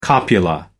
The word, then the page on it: copula: American English